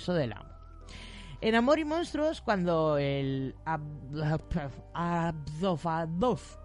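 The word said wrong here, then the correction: Absorbaloff: no sé, no pronunciar esto, Absorbalov. Absorbalov, vale.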